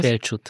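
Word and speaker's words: Felcsút: Wir haben da vor allem uns auch angesehen, dass, weil David war in Orbans Heimatort Felschut, ich weiß nicht, wie spricht man es aus? – Felschut. – Felschut, genau.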